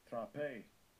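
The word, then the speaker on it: Trape (τροπή): Trape.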